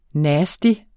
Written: nasty

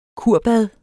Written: kurbad

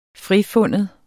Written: frifinde

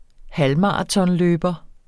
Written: halvmaratonløber

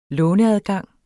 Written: låneadgang

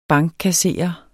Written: bankkasserer

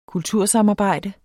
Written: kultursamarbejde

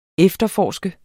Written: efterforske

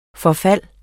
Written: forfald

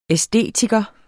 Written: æstetiker

